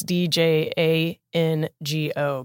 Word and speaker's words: Django: And then there's a silent D in there. So it's D J A N G O, Bixly Django.